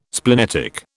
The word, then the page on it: splenetic: British English